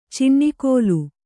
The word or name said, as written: ಚಿಣ್ಣಿಕೋಲು